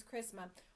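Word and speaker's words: chrisma (χρίσμα): It means chrisma, or that's how you say it in the Greek, I should say.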